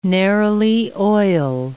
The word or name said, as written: neroli oil